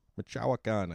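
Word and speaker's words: Michoacán: Michoacan, I said that right that time, but not with a little flair.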